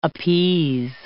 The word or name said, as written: appease